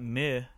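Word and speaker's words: MØ: So check out Meh is actually how it's pronounced.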